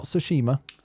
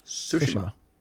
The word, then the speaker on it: Tsushima: The next one is Ghosts of Tsushima. Not Tsushima. – Tsushima. – Yeah, the stress on the so.